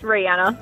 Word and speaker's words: Rihanna: It's Rihanna.